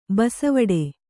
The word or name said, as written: ಬೆಸವಡೆ